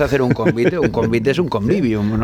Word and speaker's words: convivium: Los romanos, a ver si lo digo bien, convivium. – Sí, convivium, que de hecho hay muchas palabras que hoy utilizamos, vienen precisamente de ahí.